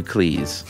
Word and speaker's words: Cleese: He noted that his name is pronounced to rhyme with cheese rather than fleece. In fact, his surname was originally Cheese, but his father changed it to Cleese.